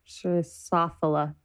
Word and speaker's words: Drosophila: Girofila. Drosophila. That's how you say it.